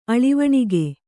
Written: ಅಳಿವಣಿಗೆ

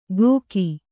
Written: ಗೋಕಿ

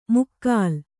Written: ಮುಕ್ಕಾಲ್

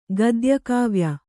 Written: ಗದ್ಯಕಾವ್ಯ

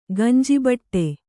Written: ಗಂಜಿಬಟ್ಟೆ

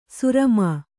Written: ಸುರಮಾ

